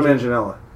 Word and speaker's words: Manganiello: Manganiello? Joe Manganiello.